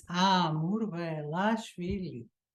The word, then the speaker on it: Amurvelashvili: It's Amurvelashvili.